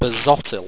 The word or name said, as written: basotil